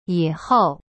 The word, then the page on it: 以后: yihou